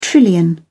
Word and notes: trillion: British English

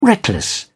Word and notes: reckless: British English

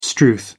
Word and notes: strewth: American English

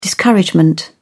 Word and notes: discouragement: British English

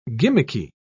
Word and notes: gimmicky: British English

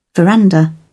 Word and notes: veranda: British English

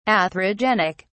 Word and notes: atherogenic: British English

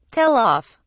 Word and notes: tell off: British English

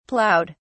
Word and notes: plowed: British English

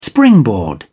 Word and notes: springboard: British English

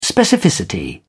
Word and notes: specificity: British English

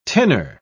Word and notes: tinner: British English

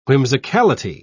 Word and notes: whimsicality: British English

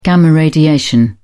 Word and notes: gamma radiation: British English

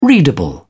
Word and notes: readable: British English

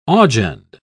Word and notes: augend: British English

